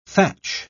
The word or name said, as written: thatch